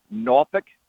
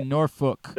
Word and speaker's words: Norfolk: Um, I say it more like N O R F O O K, like Nor, and then rhymes with book. That's Nor- how I okay Norfolk.